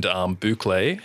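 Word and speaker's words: bouclé: Now, beside corduroy and boucle, are there, and if I pronounce that correctly, of course. – You nailed it.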